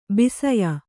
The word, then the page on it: ಬಸಿಯ: Kannada